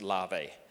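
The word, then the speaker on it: lave (לב): But the word for heart in the Hebrew is, I think it's pronounced lave or, I think it's lave. It's the spelling is L-E-B and it's pronounced lave.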